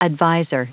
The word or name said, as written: advisor